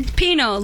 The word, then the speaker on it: Pinot: Pinot, like Pino, the wine.